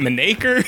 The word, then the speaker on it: Menaker: Is it Menaker? – You got it right.